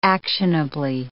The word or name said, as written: actionably